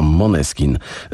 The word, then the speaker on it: Måneskin: Nazwa tego włoskiego zespołu jest duńska i czyta się to Måneskin z akcentem na pierwszą sylabę, a nazwa oznacza blask księżyca.